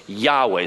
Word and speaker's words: Yahweh: four letters, YHWH. Yahweh. We believe it's pronounced Yahweh, sometimes rendered Jehovah.